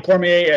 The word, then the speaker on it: Cormier: Daniel, is it Cormier. Okay, yes. Daniel Cormier as the special referee.